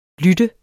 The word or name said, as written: lytte